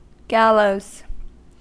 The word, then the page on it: gallows: American English